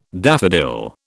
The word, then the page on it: daffodil: British English